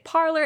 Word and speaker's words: Parler: We spoke to the leadership behind Parler and they told us that for branding reasons, they are pronouncing it parler. And then each of the posts on the site is called a parler. Yeah, it's not that we just don't know how to say the word. It is pronounced parlor. That company is called Parler.